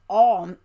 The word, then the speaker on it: Om: The yoga class starts and ends by chanting Om, which is pronounced Aum, A-U-M.